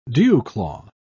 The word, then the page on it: dewclaw: British English